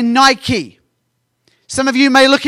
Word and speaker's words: Nike: In America, we say Nike. In England, we say Nike. The actual pronunciation of this word is Nike. But it's the same word, N-I-K-E.